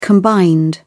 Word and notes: combined: British English

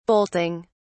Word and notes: bolting: British English

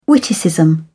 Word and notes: witticism: British English

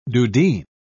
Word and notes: dudheen: British English

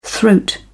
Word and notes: throat: British English

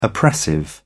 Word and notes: oppressive: British English

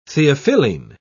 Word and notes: theophylline: British English

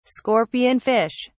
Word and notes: scorpion fish: British English

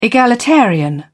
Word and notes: egalitarian: British English

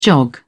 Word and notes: jog: British English

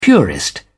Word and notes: purist: British English

